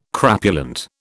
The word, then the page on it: crapulent: British English